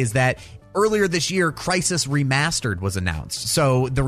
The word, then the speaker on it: Crysis: It's spelled C-R-Y. – Ooh, it's Crysis, so it's Crysis. Like crying.